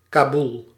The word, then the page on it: Kaboel: /ˈkabul/